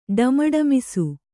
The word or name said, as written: ಢಮಢಮಿಸು